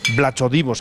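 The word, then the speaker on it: Vlachodimos: Realmente es Bla jodimos con V, empieza el. Bla chodimos, que es como se escribe, pero bueno, que es como se pronuncia.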